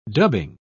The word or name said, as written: dubbing